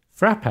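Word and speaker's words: frappé: It is pronounced frap. Well, there you go. There's no pay on the end.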